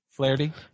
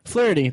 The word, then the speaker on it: Flaherty: Flaherty. – Flaherty.